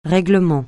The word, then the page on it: règlement: francais